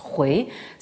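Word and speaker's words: hui (回): Là aussi, la prononciation moderne importe peu, ça se prononce hui, ça veut dire revenir.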